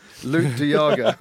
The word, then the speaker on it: Luke de Jager: They were looking at Yaeg Delude. Is that how you say his name? – Luke de Jager.